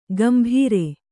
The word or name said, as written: ಗಂಭೀರೆ